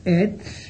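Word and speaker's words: ec: El sufijo et, e-c, la c se pronuncia ts, recuerdo, et, indica cualidad abstracta, lo contrario que el sufijo h, que significa cosa concreta, como acabamos de decir.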